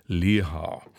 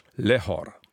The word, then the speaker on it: Lehár: Aus diesem Grunde sei sein Name selbstverständlich auf der ersten Silbe zu betonen, also Lehár, ungarisch Lehor.